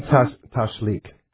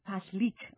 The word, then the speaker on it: Tashlich: Tashlich. – Tashlich.